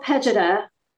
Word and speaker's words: Pejeta: Is that correct? Old Pajeda? – Oh, Pejeta. – Pejeta.